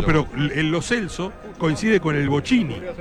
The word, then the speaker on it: Lo Celso: Hernán Feller hoy tiene, como todos los relatores, el cruel desafío de haberse enterado hace 2 horas que es Lo Celso. Porque los jugadores, papel en mano, dijeron ante las cámaras de FIFA cómo se pronuncian sus nombres. Y el número 11 argentino dijo Giovanni Lo Celso. O sea que pronunciamos mal su apellido más de 10 años.